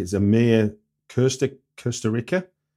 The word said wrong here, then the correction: Kusturica: Kusturica. – Okay. Uh, I don't know how to pronounce it in his language. We call it Kusturica.